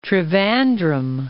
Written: Trivandrum

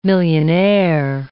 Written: millionaire

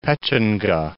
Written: Pechenga

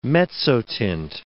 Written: mezzotint

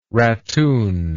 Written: rattoon

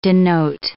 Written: denote

